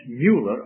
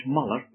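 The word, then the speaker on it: Müller: And then we have a man like George Mueller or George Muller, whichever way you want to pronounce his name.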